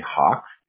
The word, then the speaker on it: Hawk: So my name's Sahak, S-A-H-A-K, okay. But a lot of people just call me Hawk, H-A-W-K.